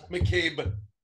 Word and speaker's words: McCabe: McCabe. Pronounce it correctly. – McGabe. – There's two C's, a B, an E, and A.